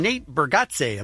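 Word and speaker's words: Nate Bargatze: We welcome to the studio comedian Nate Bergazze.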